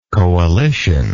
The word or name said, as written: coalitioner